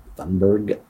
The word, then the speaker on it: Thunberg: Thunberg.